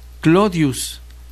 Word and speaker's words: Claudius: En esta época, un demagogo de la aristocracia, de la aristocrática familia Claudia, deseoso de popularidad, decía llamarse Clodius, que era como el pueblo, la mayoría, pronunciaba el nombre de Claudius.